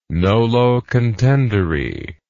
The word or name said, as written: nolo contendere